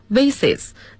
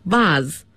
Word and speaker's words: vase: 那么刚才周老师的读音是 vases，那么还有一个发音是 vase，一个是 vase，一个是 vase，英音 vase，美音 vase。